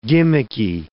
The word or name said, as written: gimmicky